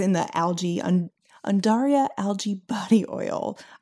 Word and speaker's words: Undaria: And, you know, I for a while was pronouncing it Undaria like an idiot. So if you, like me, cannot read, I've just helped you sound smart because it's actually Undaria, Undaria algae body oil.